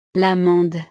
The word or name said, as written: l'amande